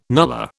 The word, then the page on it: nullah: British English